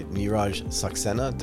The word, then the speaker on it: Neeraj Saxena: We are joined by Neeraj Saxena.